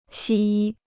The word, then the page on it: 西医: xī yī